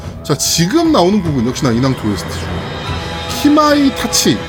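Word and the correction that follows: カマイタチ: 가마이 됐치아 카마이 타치. 카마이 타치. 이걸 내가 왜 키라고 읽었을까 카마이 타치.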